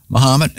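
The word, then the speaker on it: Mahomet: And then tomorrow it's Muhammad, Illinois. Am I pronouncing it? I think so, yeah. Muhammad, as in Ali.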